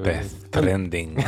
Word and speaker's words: Death Stranding: C'est Death Stranding, je dois le prononcer très très mal. – Pas non, c'est Simon le... Death Stranding.